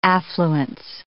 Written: affluence

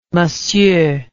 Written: m'sieur

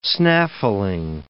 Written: snaffling

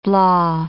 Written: blaw

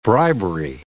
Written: bribery

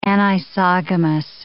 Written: anisogamous

